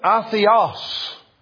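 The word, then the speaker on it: atheos (ἄθεος): It is pronounced atheos.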